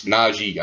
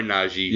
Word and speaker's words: Najee: They corrected me on – I was listening to a podcast, and they're like, it's Najee. I was like, my bad. So, Najee. – My bad. Sorry, Najee.